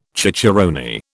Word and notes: cicerone: British English

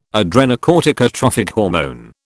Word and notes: adrenocorticotrophic hormone: British English

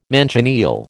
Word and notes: manchineel: American English

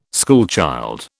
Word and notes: schoolchild: British English

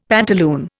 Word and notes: pantaloon: Indian

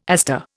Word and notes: aster: Indian

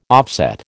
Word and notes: offset: American English

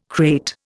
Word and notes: krait: Indian

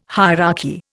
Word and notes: hierarchy: Indian

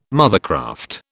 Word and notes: mothercraft: British English

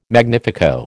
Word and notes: magnifico: American English

